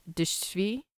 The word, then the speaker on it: drzwi: The next word is drzwi, which is spelt D R Z W I, and it means door.